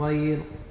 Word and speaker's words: غير: غير غير غير غير حرف الغين هنا مفخم فلا فلا يرقى يعني غير غير غير وحتى ينطق به على وجه صحيح يكون بالمقارنة مثل الغين الذي في كلمة غليظ.